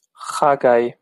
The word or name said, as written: chagai